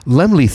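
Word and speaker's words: Laemmle: it's in Pasadena at the Lamar, how's it pronounced? Lamar Theater? L-A-E... Lamar, oh shit. – Lemley? – No. Is Lemley. It Lemley? Oh Lemley. My God, I sound such a, sorry, I sound like such an illiterate.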